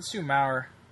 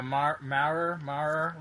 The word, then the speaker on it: Maurer: Let's do Maurer. – Just like Brandon Morrow, but Brandon Maurer. – Yeah, Maurer.